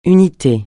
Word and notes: unité: francais

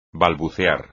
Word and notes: balbucear: espagnol

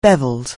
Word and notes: bevelled: anglais

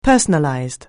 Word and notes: personalized: anglais

